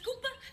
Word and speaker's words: Kapoor: Oh, we wouldn't miss the Kapoor's barbecue for the world. – Pronounced Cooper.